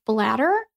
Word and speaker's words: Blatter: His last name is spelled B-L-A-T-T-E-R. It sounds like your pee-pee bladder, but it's not. It's a different one.